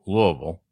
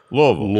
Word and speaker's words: Louisville: Louisville. But at least I said it right. – Say it properly. You have to go Louisville. – Louisville.